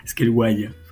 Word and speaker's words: Scaleway: Scaleway. Enfin, Anthony, il ne sait pas prononcer, mais c'est Scaleway.